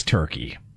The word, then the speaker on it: Turkey: I'm not going to pronounce it Turkey A, Turkey O, Turkey I. It's Turkey.